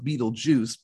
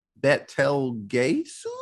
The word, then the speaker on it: Betelgeuse: It's spelled like the constellation, but it's pronounced star. – Yeah, it's pronounced Beetlejuice, but the way he says it three times, but it's completely wrong. He's like, Betelgeuse?